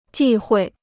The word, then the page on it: 忌讳: jì huì